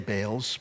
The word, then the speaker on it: Baal: It's in plural there, meaning there were many Baals, but it actually is, should be, it's, whenever we see two A's together in English, we, we use the, the expression A, Baals, but it's probably better pronounced Baal.